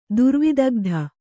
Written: ದುರ್ವಿದಗ್ಧ

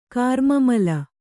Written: ಕಾರ್ಮಮಲ

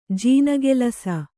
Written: ಜೀನಗೆಲಸ

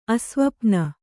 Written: ಅಸ್ವಪ್ನ

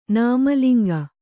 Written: ನಾಮಲಿಂಗ

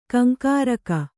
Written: ಕೆಂಕರಿಕೆ

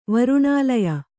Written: ವರುಣಾಲಯ

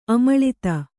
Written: ಆಮಿಳಿತ

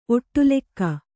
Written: ಒಟ್ಟುಲೆಕ್ಕ

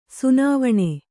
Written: ಸುನಾವಣೆ

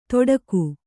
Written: ತೊಡಕು

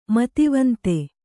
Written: ಮೇಧಾವಂತ

